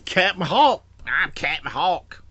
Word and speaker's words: Cap'n Hawk: Well, he's got multiple names, but in that issue, let me say his name properly. It's Cap'n Hawk. – I forgot it was Cap'n. – C-A-P apostrophe N. Cap'n.